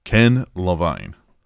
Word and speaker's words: Ken Levine: Ken Ken Devine or what? How do you say his name? Ken Levine.